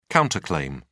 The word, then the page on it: counter claim: British English